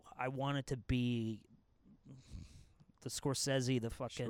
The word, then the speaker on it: Scorsese: The fact that I said Scorsese, as he knows as a film student, like, I'm pretentious alone because that is how you say his name.